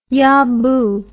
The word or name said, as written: yah boo